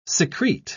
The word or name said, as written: secrete